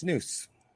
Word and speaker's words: snus: So snus apparently is snus. Something that's pronounced snus. – Snus, S-N-U-S? – Yep.